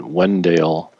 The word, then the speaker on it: Wendell: although it's just down the road in Wendale, spelled Wendell, but pronounced Wendale by the locals in Wendale, North Carolina.